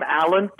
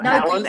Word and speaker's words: Alan: My name, Alan Pratt. – No, Alan, A-L-A-N, Pratt, Pratt.